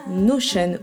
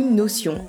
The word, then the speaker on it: Notion: Cet outil s'appelle Notion ou Notion, à vous de voir la prononciation que vous préférez.